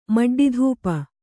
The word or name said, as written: ಮಡ್ಡಿಧೂಪ